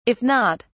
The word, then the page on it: if not: British English